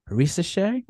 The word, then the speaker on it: Risacher: I actually went to watch Melvin Ajinka and Zachary Risacher. I'm in France and I've been pronouncing the names like an American, and then I learned that the ER in French makes an A.